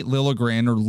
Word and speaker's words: Liljegren: But I think Timothy Liljegren is an interesting story. It's when you are drafted. – I like that proper pronunciation. Miller would be proud.